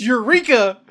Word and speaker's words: Eureka: It's Eureka. That's how that word is pronounced in America.